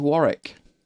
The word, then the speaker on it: Warwick: There is a W in the middle of it, but it's not. It's Warwick.